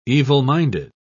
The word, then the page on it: evil-minded: British English